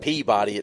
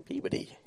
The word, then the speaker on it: Peabody: I try, it's not Peabody, it's Peabody.